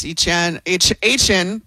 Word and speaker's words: Etienne: Travis Etienne, that's how you say it.